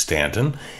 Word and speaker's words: Staunton: We went to Staunton, Virginia, which is spelled like it should be Staunton, but it's not. It's Staunton.